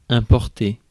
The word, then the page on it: important: American English; /ɪm.ˈpɔɹ.tn̩t/